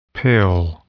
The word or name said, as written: pale